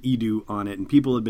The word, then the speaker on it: Eadu: Nobody necessarily knew for sure, but it seems like we have official confirmation now that Eadu, that's how you pronounce it, even though it's spelled E A D U, is a new planet appearing in the Star Wars universe.